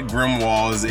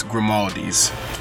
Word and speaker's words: Grimaldi's: It's not Grimwalds. It's Grimaldi's. It's Grimaldi's.